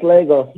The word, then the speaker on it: Lagos: I don't want to mispronounce it, but I think it's Lagos. – Yeah, it's Lagos. Lagos all day.